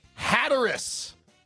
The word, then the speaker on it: Hatteras: Hatteras. – Spell it. – H-A-T-T-E-R-A-S.